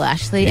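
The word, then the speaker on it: Ashleigh: Yeah, L-E-I-G-H, the best way to spell Ashley.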